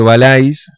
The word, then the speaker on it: Valais: Valais, se escribe Valais, se pronuncia Valais en francés.